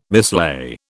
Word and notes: mislay: British English

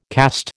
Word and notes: caste: American English

